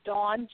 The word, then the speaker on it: Dawn: It's Dawn, just like the morning, Dawn Marie Westmoreland.